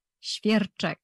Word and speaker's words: Świerczek: Her name is Izabela, and I'm going to say your last name so wrongly, and you can smack me. Świerczek? – Świerczek. – Świerczek. – Yes, you're good. – Oh, I was close, huh? – Yeah, very close.